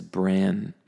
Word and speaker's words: bran: So the thing in your head, your noodle, that is your brain. That's a long A sound, just like when you say the letter A, brain. But then the cereal, or the grain, the thing that's in cereal, is bran. Bran.